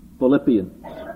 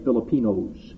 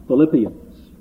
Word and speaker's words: Philippians: Notice the pronunciation of that, please. Philippians. Time Dr. Kearns announces a text from this, I think he's going to say Filipinos. Philippians.